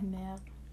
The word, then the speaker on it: merde: Like instead of I just be like merde. That that's not how you pronounce it, but I like doing with the little eh. It's more like merde.